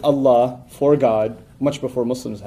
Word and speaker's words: Allah: So we call him Allah. His name is Allah. That would be spelled in English A-L-L-A-H, an emphasis on the L, okay, Allah.